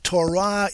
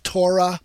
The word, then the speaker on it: Torah: Now, Torah is normally translated law. Sometimes in English, it's pronounced Torah. But the more proper Hebrew pronunciation is Torah.